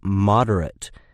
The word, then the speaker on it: moderate: And it is interesting, too, that moderate can also be used as an adjective with a completely different meaning. And for this usage, it is pronounced moderate.